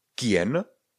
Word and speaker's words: quien: Jsou to slova jako kien, Q-U-I-E-N, kien, kien, kien, znamená to kdo. Nebo velmi typicky bude právě slovo ke. Ke. Ke se píše Q-U-E ke znamená to co, nechť, ať, kéž, používá se jako různá spojka, jako zvolání, budeme ho mít hodně často.